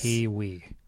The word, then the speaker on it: Keywe: It's spelled Keywe. – Yes. – Kiwi. – As in typewriter key and we need to press it.